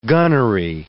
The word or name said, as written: gunnery